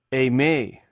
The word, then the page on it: eimh: greek